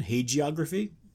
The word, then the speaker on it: hagiography: Either one's correct. They're actually both listed as pronunciations. – Okay. – I generally go with the affricate soft G version, hagiography, but either one is acceptable.